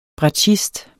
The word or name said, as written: bratschist